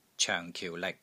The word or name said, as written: COENG4 KIU4 LIK6